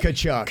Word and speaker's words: Tkachuk: Tkachuk.